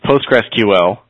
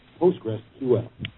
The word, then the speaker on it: PostgreSQL: Okay, the correct pronunciation is PostgreSQL. – Okay. – There's even a handy-dandy MP3 file that tells you what it is. – Wow, cool. – It sounds like this. – PostgreSQL.